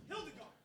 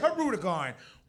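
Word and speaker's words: Hirudegarn: Hildegard. Yes, I will accept that pronunciation, although I personally say Herudegard.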